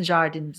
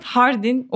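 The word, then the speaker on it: Jardín: Biraz anlatır mısın Jardin bize? – İspanyolca'da hardin okunuyor.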